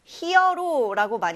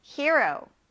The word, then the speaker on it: hero: 히어로라고 많이 들어보셨을 거예요. 영웅이라는 뜻인데 이게 사실상 뭐 영화나 이야기의 중심이 되는 주인공으로도 해석이 됩니다. 히어로 아니고 hero라고 이렇게 발음해 보세요. 자, 따라해 보세요. hero, hero, hero.